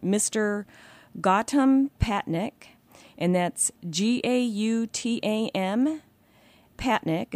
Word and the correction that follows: Patnaik: is Patnaik, Division of Water Quality.